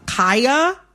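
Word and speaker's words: Khia: I am going to, in this conversation, pronounce Kaya, the rapper, with the long I. Kaya, okay? My neck, my back, mm-hmm. for those who need a refresher. Kaya, okay? Mm-hmm. The Kia Asylum is a mispronunciation of her name.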